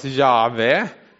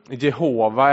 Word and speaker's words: Jahve: Men man tror att det ska uttalas Jahve. Det är J-H-W-H då. Och det förekommer över 6000 gånger. Jehova är det också en del som uttalar det som så.